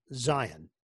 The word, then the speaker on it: Zion: He actually pronounces his name Zion, like you said. But in an interview with Jay Billis, when he was still at Duke, he asked him about the pronunciation of his name. And he said, well, it's Zion, but I like when the announcer says Zion. It just makes me sound more powerful.